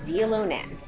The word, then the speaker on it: Xilonen: It's that you spell it X-I-L-O-N-E-N. That's the name of the restaurant, pronounced Zilonen.